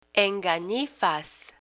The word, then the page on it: enganifas: Spanish